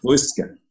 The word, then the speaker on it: Fauske: Fauske.